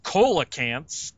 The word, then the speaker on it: coelacanth: or coelacanths, as the scientist calls it. They're called coelacanth. The C is pronounced like an S.